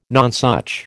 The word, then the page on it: nonsuch: American English